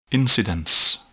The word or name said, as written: incidence